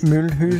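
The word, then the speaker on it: Mulhouse: Og så er det målbyen, der er Milus. – Det er startbyen. – Det er startbyen, og udtalen Mølhus.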